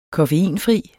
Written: koffeinfri

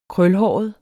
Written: krølhåret

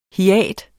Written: hiat

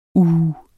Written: uh

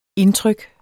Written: indtryk

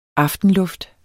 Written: aftenluft

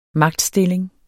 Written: magtstilling

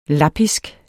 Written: lappisk

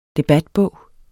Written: debatbog

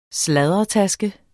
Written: sladdertaske

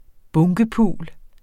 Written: bunkepul

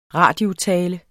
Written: radiotale

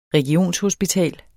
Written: REGIONSHOSPITAL

